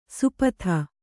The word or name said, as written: ಸುಪಥ